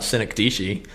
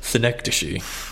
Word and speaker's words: synecdoche: I'm pretty sure you just internet doctored me about synecdoche. – I have to push up my glasses while saying, well, actually. – Actually. – Well, actually, Wikipedia and the Oxford Dictionary believe that it is pronounced synecdoche.